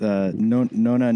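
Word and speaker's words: nonna: That's how you say it's nona, no-no.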